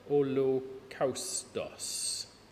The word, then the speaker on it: holokaustos: Thing to remember is that in Greek, the upside down Y is the letter lambda, which is pronounced L. So you can read it, it says holokaustos.